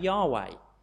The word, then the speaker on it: Yahweh: It's the four letters YHWH, and we tend to pronounce that Yahweh, but you'll very rarely see that written in the Bible because it's normally translated as Lord in small capitals.